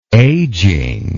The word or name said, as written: ageing